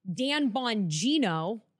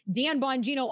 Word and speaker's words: Dan Bongino: Pam Bondi, Kash Patel, Dan Bongino. I have to say it like that because I messed up his name the other day and everybody's like, you butchered that. And I'm like, yeah, I know.